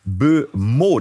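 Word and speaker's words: Mbeumo: Brian, ausgesprochen Bumeau, das ist ein Flügelspieler, richtig spannender Mann von Brentford.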